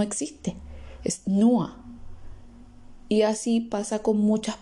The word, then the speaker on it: nur: En podcasts anteriores aprendimos que la unión de la U con la R se pronuncia UA y no UR. Entonces la palabra NUA solo se pronuncia NUA y no NUR.